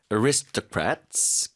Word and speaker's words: aristocrats: So fine dining has its roots in the dining culture of French aristocrats. 귀족이란 뜻이죠. 그 발음 좀 도와드릴게요. – Aristocrats. – Aris, 이 음절 힘주시고, aristocrats, 한번 더요.